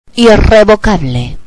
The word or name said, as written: irrevocable